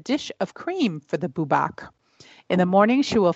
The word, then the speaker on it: Bwbach: Well, you know how it's spelled? B-W-B-A-C-H. Or Bubak.